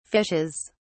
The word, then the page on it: fishes: British English